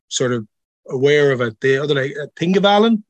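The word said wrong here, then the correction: Þingvellir: So most people say Thingvellir, like Thingvellir.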